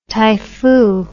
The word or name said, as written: typhoo